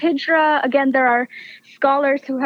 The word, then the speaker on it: Hijra: And the character of Lovely, who is a trans woman, and tell me if I'm pronouncing this correctly, is it Hijra? – Ahijra, yes.